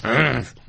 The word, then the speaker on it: Reims: How do you say that properly? – Reims. Which is what you say when you're about to open a bottle of champagne.